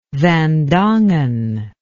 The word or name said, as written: van dongen